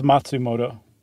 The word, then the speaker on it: Matsumoto: Matsumoto, yeah? – Yeah. – All right. And it was called Tower of Waves. I always depend on you for Japanese pronunciation. – Well, so then technically it's Matsumoto.